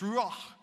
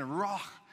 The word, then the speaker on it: ruach: and it's ruach, and it has this guttural sound at the end, ruach.